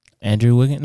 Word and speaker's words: Andrew Wiggins: Like, you know, there's people out here that really think that Andrew Wiggins got the I probably said his name wrong, huh? – Andrew Wiggins?